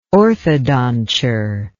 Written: orthodonture

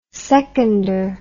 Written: seconder

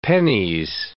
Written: pennis

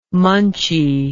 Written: munchy

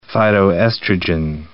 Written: phytoestrogen

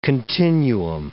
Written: continuum

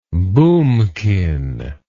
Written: boomkin

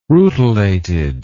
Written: rutilated

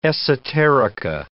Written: esoterica

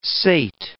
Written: sate

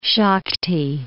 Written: Shakhty

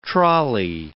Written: trolly

